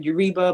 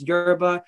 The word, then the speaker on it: Yoruba: But I go up to the tour person and I'm like, hey, I just want to let you know that you pronounced Yoruba wrong. You said Yoruba, but it's Yoruba.